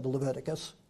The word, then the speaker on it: Leviticus: So if you just walk through it with me, if you start in verse 4 of Exodus, Leviticus.